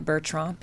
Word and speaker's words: Bertrand: Maria Bertrand, B-E-R-T-R-A-N-D, yeah, you can find it on Amazon worldwide.